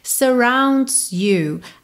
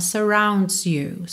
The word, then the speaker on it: surrounds: Everyone surrounds you. Atenção para fazer esse S final da palavra surrounds soar, tá? Porque é a conjugação do verbo. Então, surrounds you, surrounds you.